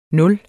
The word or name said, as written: nul